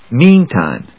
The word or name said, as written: meantime